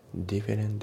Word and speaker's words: different: で、発音ですけど、 different の t が抜けますね。 different days、 different days じゃなくて different days だね。